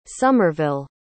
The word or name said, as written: somerville